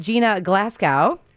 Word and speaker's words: Gina Glasgow: Please welcome Gina Glasgow. Did I say that right? – Yes, you did.